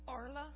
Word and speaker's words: Arla: I'm Arla. – Say it again. – Arla. – How do you spell that? – A-R-L-A.